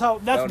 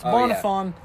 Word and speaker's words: Bonafon: That's Bonafon. Bar's pronunciation. It's Bonafon.